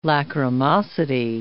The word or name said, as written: lachrymosity